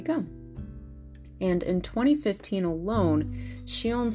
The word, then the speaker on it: Sion: The director's name is pronounced Shion Sono, with Shion being the on readings of the kanji for child, she, and warm, on.